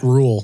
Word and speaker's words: Rhule: It's Matt Rhule.